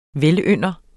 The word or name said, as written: velynder